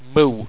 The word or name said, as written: mu